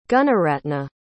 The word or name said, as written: gunaratna